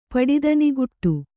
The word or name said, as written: ಪಡಿದನಿಗುಟ್ಟು